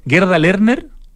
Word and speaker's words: Gerda Lerner: Gerda Lerner, ¿está bien pronunciado? – Sí, sí.